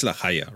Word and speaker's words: La Haya: It's La Haya, right? I want to be sure I'm saying it correctly. – Yeah, you say it correctly because Gascon is more closer to Spanish than French. So people in France just butchering the name every time, but you know.